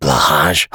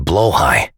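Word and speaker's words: Blåhaj: Oh, is that Blahage? Oh, they are so comfortable, aren't they? You know, my wife tells me it's actually pronounced Blowhigh.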